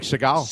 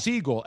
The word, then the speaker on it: Siegel: We spell like Seagal. – Siegel. S-I-E-G-E-L. – Yeah, that's how you pronounce it.